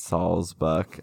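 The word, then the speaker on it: Sawsbuck: It's Saul's buck. I always say Swassbuck.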